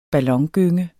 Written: ballongynge

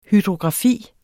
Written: hydrografi

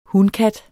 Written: hunkat

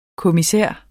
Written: kommissær

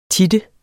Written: tit

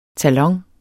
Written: talon

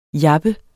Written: jappet

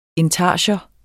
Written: intarsia

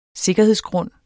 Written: sikkerhedsgrund

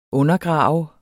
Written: undergrave

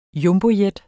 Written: Jumbojet

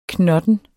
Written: knotten

